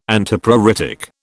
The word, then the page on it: Antipruritic: British English